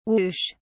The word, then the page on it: woosh: British English